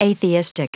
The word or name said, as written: atheistic